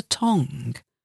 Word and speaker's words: tongue: So in English, we might talk about your native language or your first language as your mother tongue. Notice the silent U in that word.